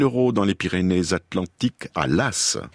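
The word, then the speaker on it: Laàs: Nous sommes toujours dans ce village de Las, en effet. Ça s'écrit L-A-A-S, mais il y a un accent grave sur le deuxième A, c'est ça, Las.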